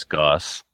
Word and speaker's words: Goos: Goose, it's Goss. – Goss? Yeah, G-O-O-S is Goss? – It is.